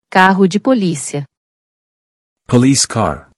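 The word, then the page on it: Carro de polícia: Portuguese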